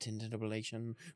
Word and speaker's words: tintinnabulation: It's pronounced tintinnabulation.